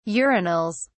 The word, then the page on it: urinals: British English